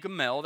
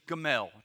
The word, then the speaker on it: Gamaliel: But a Pharisee in the council named Gamaliel, that's the easiest pronunciation, just Gamaliel. Just remember that, Gamaliel.